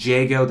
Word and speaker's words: Djago: Across the road from the rye, it's called Jago, that's spelled D-J-A-G-O.